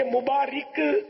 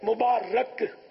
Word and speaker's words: مبارک: یہ مبارک غلط العوام ہے, غلط تلفظ ہے. مبارک یہ درست تلفظ ہے.